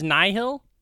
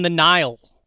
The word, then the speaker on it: Nihil: And they're basically is that how it's pronounced? Is Nihil? – Uh-huh. – Oh, because I've been reading it, and I thought it was I was calling them the Nile, like Niles.